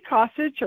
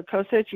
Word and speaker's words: Kosich: It's Marci Kosic or Kosic, either one.